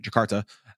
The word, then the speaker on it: Jakarta: Jakarta, as Rami in his journey to love.